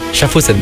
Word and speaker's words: Schaffhausen: Tu le dis bien, Schaffhausen.